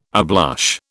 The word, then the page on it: ablush: British English